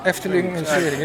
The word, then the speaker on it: Eftelingenvisering: Maar wat plaatsvond daar was een Eftelingisering. Als iets te veel. Nee, Eftelingenvisering.